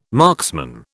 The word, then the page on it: marksman: British English